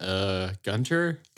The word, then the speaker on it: Gunter: And I'm like, Gunter? – Gunter. There's no H. – It's silent. It's a non-existent silent H. It's really tricky.